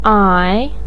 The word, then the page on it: ai: ai3